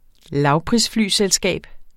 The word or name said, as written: lavprisflyselskab